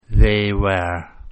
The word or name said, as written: they were